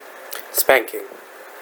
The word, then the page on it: spanking: American English